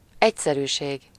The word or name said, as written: simplicité